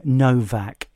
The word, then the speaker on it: Nowak: Google Henry Novak. It is spelt N-O-W-A-K.